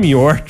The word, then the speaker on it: Muir: Le piano a été retrouvé par 15 bénévoles du John Muir... Muir? – Du John Muir Trust, prononce correctement.